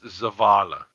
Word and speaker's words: Zavala: He was the voice of Zav Allah, and he was also a hardcore fan. – It's pronounced Zavala. – Zavala.